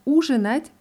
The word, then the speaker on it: ужинать: Bei dem Verb ужинать bleibt die Betonung auf dem ersten U in allen Formen.